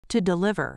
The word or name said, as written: to deliver